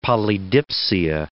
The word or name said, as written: polydipsia